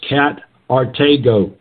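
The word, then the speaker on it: katargeō: The word abolish is katargo, K-A-T-A-R-G-E, long O.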